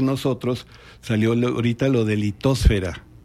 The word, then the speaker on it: litosfera: Según la RAE, soy horrible, litosfera. – La única que tiene la pronunciación que nosotros usamos como mexicanos es atmósfera. Lo demás debe ser hidrosfera, litosfera, biosfera.